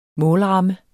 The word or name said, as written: målramme